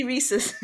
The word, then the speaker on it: Reese's: I don't say Reese's, I say Reese's. – But isn't it? – There's an extra E at the end. – Am I saying it wrong? – It rhymes with pieces.